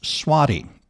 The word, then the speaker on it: Svaty: His name is spelled S-V-A-T-Y, pronounced Swaty.